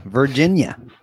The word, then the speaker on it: Virginia: Am I pronouncing it right? Virginia? – Yeah, Virginia, like a verge, and then it's in.